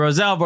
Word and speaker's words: Rosell: Thank you so much for joining us through Rosell, Orlando City. All right. – We should have pronounced it Rosell. – Why? – That's how he pronounced it. That's how the Catalan pronunciation.